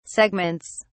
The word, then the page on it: segments: British English